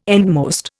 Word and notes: endmost: Indian

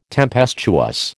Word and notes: tempestuous: American English

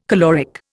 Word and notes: caloric: Indian

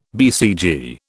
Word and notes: BCG: British English

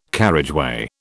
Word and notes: carriageway: British English

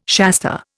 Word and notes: Shasta: Indian

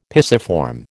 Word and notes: pisiform: American English

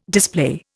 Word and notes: display: Indian

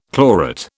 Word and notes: chlorate: British English

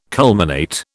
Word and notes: culminate: British English

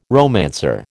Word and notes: romancer: American English

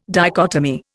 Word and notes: dichotomy: Indian

